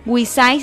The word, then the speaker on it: Wix Site: Wisai se escribe W-I-X S-I-T-E, ¿vale?